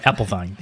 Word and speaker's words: Apfelwein: One thing you definitely should try is a different kind of beer that they have, and it's called Apfelwein. Did I say that right? – Pretty much apple wine, but yeah apple vine. Close enough.